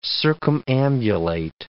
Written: circumambulate

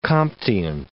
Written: Comtean

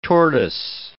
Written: tortoise

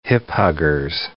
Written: hip-huggers

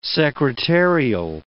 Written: secretarial

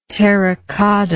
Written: terracotta

